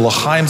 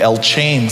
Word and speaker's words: L'chaim: Members of the L'chaim Society, or as I pronounced it before I walked into his place, the El Chain Society, that he created a space where all of us came together.